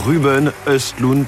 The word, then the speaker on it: Ruben Östlund: Et cette fois, c'est Vincent Lindon qui avait énoncé les mots magiques euh, euh, Ruben Östlund, puisque c'est ainsi qu'on prononce apparemment le nom de ce réalisateur suédois de Palme d'Or, The Square et Triangle of Sadness.